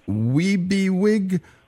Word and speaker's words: WEBEWIG: And there's an acronym pronounced WEBEWIG. We'll right. – be here when you're gone.